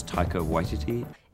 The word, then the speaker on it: Taika Waititi: How do you say his name? Taki? Tahiki? Whatever the fuck. You know who I'm talking about. – My name is Taika Waititi.